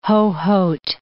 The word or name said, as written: Hohhot